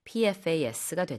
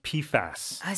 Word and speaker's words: PFAS: Yeah, I should correct myself. I think it's pronounced PFAS. – Is that how you say it? – Yeah, Not PFA.